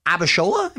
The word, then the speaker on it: Abishola: You're also part of this new CBS sitcom, Bob Hart's Abishola. Am I pronouncing that? – Yes. – Correctly? – Mm-hmm.